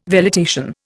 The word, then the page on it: velitation: Indian